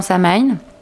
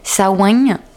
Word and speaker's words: Samhain: Oui, alors la Samhain, c'est alors on le prononce pas forcément bien en disant Samhain. J'ai appris donc par Marie-France Houdard, notre historienne limousine, que ça se disait plutôt Samhain par ici, mais en tout cas c'est une fête païenne, une fête d'origine celtique, pour célébrer l'entrée dans la saison sombre.